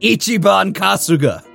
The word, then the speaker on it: Ichiban Kasuga: No, it's pronounced Ichiban Kasuga.